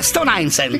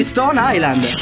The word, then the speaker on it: Stone Island: E questi jeans della Stone Hour? Questi jeans cos'è che è? Questi jeans della Stone Ainsel. Questi jeans della Stone Islands. – Ma perché scrivono come si legge? – Si chiama così, Stone Island.